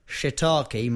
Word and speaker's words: shiitake: Chia, there is, I've got to say this right, shiitake mushroom. – Shiitake. – Don't say that wrong.